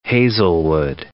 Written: Hazelwood

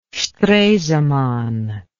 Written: stresemann

